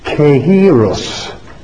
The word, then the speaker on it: Kairos: K-A-I-R-O-S, pronounced Greek, transliteration, pronounced Kairos, means opportunity.